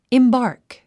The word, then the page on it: embark: imbάːrk; インバーク